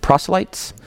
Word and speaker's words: proselytes: Is that how you pronounce it? Proselytes? Proselytes.